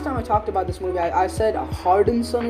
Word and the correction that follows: Harrelson: or something like that, or I don't know, Harrison, I think, but I'm really sorry about that. It's Harrelson.